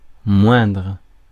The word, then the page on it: lower: /ˈloʊ.ɚ/; /ˈləʊ.ə/